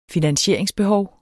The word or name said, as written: finansieringsbehov